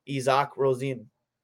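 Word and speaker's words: Isak Rosén: I want to say, was it Rosen? – He got drafted out of Sweden. – I think we got the right pronunciation. Izak Rosin.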